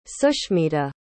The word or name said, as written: sushmita